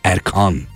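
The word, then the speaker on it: Erkân: A inceltmesi şapkası varsa A'da devletin sayılı kimseleri anlamına gelebilir. Ergan, Erkan. Erkan, Erkan. Öyle hani Erkan olarak okunur.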